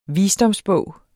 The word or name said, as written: visdomsbog